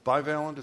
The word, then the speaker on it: bivalent: When you have booster rates at, 15 or 16%, the bivalent, is that how you pronounce it? Bivalent perfect.